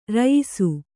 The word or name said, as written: ರಾಯಸ